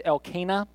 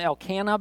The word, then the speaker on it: Elkanah: Now, I want to warn you ahead of time, the correct pronunciation of his name, I believe, is Elkanah. But I have always called him Elkanah because I thought it was really cool that it rhymed with Hannah.